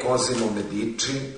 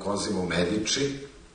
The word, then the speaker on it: Cosimo Medici: Znači nije Cosimo Medici, nego Cosimo Medici i tu bih završio.